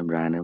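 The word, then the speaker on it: Brian: যেখানে ব্রায়ানের বানান হলো গিয়ে কি BRAI and brain, কিন্তু উচ্চারণ করতে হবে কি ব্রায়ান, ওই কাহিনীটা বলে সিনেমা কাহিনীটা, সো ফাকিং হিলারিয়াস।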